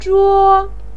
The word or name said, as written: zhuo1